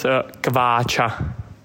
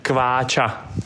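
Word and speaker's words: Kváča: Kvacha, with a long A. – Yeah, with a long A and with hash mark on the C. – Yeah. – It's a ch and a, it's a Kvacha.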